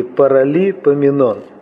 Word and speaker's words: Паралипоменон: Сегодня мы будем читать из первой книги Паралипоменон. Меня когда-то научили, как правильно ее произносить. Из двух частей. Парали, а потом Поменон.